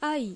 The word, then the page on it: 愛: あい; ai